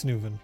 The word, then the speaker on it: Nguyen: In It's Nguyen.